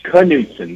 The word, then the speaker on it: Knutson: He goes, "Al Knutson," and Al steps forward and says, "It's Knutson. The K is pronounced.